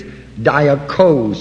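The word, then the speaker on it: Dioko (διώκω): And when you go to this analytical, you will find that the root word is Dioko, spelled D-I-O-K-O, which is both long O's.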